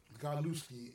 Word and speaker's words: Scott Godlewski: Godlewski.